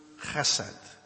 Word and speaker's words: chesed: It's the word chesed. Chesed. If you want to spell it in English, H with a dot under it, E-S-E-D, or make it C-H-E-S-E-D, chesed.